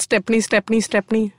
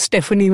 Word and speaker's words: Stepney: Stepney. – Yeah, it is. – I used to think it was Stephanie when I was small and people are mispronouncing it. – No, it's definitely a stepney, I think.